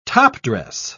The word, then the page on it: topdress: British English